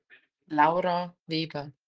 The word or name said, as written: Laura Weber